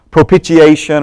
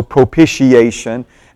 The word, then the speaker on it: propitiation: The first word is propitiation, or as I told you last week, some people do the sh in the middle, so propitiation.